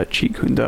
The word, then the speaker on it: Jeet Kune Do: Yeah, Jeet Kune Do.